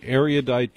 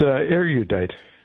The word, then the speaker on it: erudite: It's erudite. – That's what I thought. It's three syllables, right? – You can do three, but more like two. Yeah, two. Slur it.